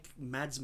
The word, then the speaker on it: Mads: I guess it's kind of like Mads Mikkelsen. – Yeah, Mikkelsen. – I think the D is silent. I'm not really sure, but basically in Denmark, he plays all sorts of like roles from like a kindergarten teacher to a comedic guy.